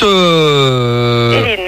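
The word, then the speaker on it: Elyn: Hélène, merci. – Hélène, non, mais c'est marrant parce que ça s'écrit E. – Elle, Y, N.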